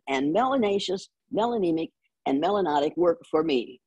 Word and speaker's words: melanotic: If you have a lot of melanin in your skin, you're melanotic, which rhymes with exotic.